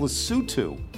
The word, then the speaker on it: Lesotho: He says, you know me, and I would have thought this was pronounced Lesotho, but it's pronounced Lesotho.